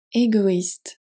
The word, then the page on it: égoïste: French